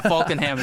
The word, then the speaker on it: Falkenham: It starts with an F. Yeah, Falkenham is how you say.